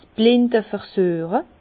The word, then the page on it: 's blinde Versüeche: Bas-Rhin; Herrlisheim